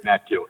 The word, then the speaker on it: Natkiel: Natkiel, correct. – Natkiel? – Natkiel, correct.